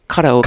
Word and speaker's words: karaoke: Because there's just karaoke everywhere in Japan. – Well, it's actually pronounced karaoke.